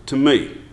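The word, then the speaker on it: to me (τιμή): The Greek word here is time. It's actually to me. It's pronounced to me. You know how it's spelled? T-I-M-E. You know how you honor your wife? T-I-M-E. Now in redneck America, that spells time.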